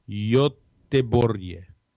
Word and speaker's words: Göteborg: So if you were curious how to pronounce Göteborg, it's the Swedish pronunciation Göteborg.